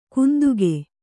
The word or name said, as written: ಕುಂದುಗೆ